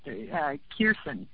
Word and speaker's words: Kirsan: The first name is, I believe, pronounced Kirsan, K-I-R-S-A-N.